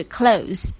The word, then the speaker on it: clothes: 大家注意一下发音，不要发成 clothes。它的标准发音有两种，一种是 clothes 或者是 close，就是跟关门的关是同一个音，注意哈，不要发成 clothes。